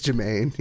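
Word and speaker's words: Jemaine: Oh yeah, it's Jermaine. Yeah. – Yeah, Jermaine. Yeah, Jermaine Clement.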